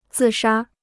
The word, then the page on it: 自杀: zì shā